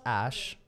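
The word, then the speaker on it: Æ: So it says, it's just X, the letter X, and A-E is pronounced Ash.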